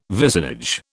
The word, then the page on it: vicinage: British English